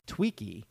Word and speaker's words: Twiki: T-W-I-G-G-Y. And that's what it sounds like. – Right. – Sometimes when they say it. Now, I was pronouncing it wrong when I said Twiggy. It's Tweaky.